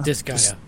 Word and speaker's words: Disgaea: is it pronounced Disgaea? Disgaea? – Disgaea.